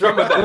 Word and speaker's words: Ramadan: Well, first of all, pronounce it. He said Ramadan. It's Ramadan.